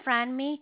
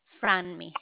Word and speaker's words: Franmi: My name is Franmi. It's spelled F as in Frank, Ranmi. Franmi.